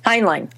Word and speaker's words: Heinlein: Heinlein.